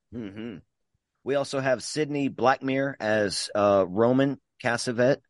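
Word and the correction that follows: Castevet: That's the Castavet. I'm saying their name wrong.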